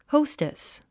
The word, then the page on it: hostess: hóustis; ホステス